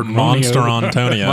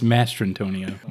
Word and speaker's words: Mastrantonio: Because that's not how you say it. You don't say a monster. – Monstrantonio. – Monstrantonio. – I believe it's Mastrantonio. – Monster? – There's an N in it? M-A-S-T-R-A-N.